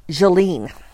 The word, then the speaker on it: Gilene: The heroine's name is G I L E N E and it is Jolene. So, and I was totally going with a hard G, so I'm glad I asked.